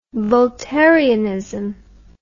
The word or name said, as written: voltairianism